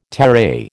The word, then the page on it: terai: American English